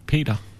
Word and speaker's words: Peter: Peter nebo Pítr? – What is the proper pronunciation of your name? – Peter.